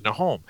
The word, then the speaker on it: Nahom: Now, they didn't have vowels in the ancient Hebrew, so this would have been pronounced as Nahom.